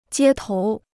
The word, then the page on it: 街头: jiē tóu